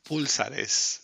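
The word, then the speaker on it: púlsares: Pulsares. – Eh, llevan un acento en la U.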